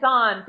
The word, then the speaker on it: Pais: I hope I'm saying his name right, but Josh Pais. – It's like Paisan. Think of Paisan. It's Pais.